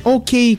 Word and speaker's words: OK: Итак, Backstreet Boys, OK. Или окей, как некоторые произносят.